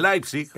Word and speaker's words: Leipzig: Y el Leipzig, el Leipzig.